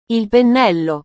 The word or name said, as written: il pennello